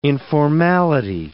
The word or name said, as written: informality